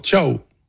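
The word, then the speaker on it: Thiaw: Tiens, d'ailleurs, comment tu m'as prononcé Thio, le remplaçant de Kimmich, ce soir? – Ciao. – Ah, ciao, en allemand, on dit ciao.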